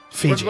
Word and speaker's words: Fiji: And Fijai. – Fiji. – What's the last one? – Fijai. – No, I think you said it wrong again. – Artesian Fijai. – I think Fiji.